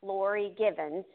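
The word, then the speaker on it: Lorie Givens: If you like this one, you can find it and several others on Facebook at Author Laurie Gibbons, and my name is spelled L-O-R-I-E. My last name is G-I-V as in Victor, E-N-S.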